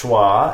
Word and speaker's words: Troyes: Because this chwa, as we mentioned at the top of the episode, is spelled T-R-O-Y-E-S, so it's not pronounced Troyes.